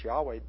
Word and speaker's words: Yahweh: And so now most scholars believe that the proper pronunciation of the word is Yahweh, though there's no way to know what the proper pronunciation is, because all you have is four consonants.